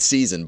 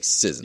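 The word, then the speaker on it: SZN: S-S-Z-N. Is that how people pronounce it? Sizen? – No, it's season, but like... It's phonetically, it's like Sizen.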